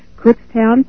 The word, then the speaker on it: Kutztown: Box 181, Cookstown, Pennsylvania, 19530.